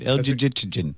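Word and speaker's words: Elgygytgyn: How would you say this? – Yeah, I think you had it right. El Jijitinjin.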